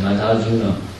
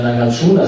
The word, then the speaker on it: Nagarjuna: Zum Beispiel, wenn man den Namen Nagarjuna hernimmt, dann ist vielleicht in unserem Geist die Repräsentation dafür eben der Name mit zwei kurzen Silben zum Schluss, also Nagarjuna.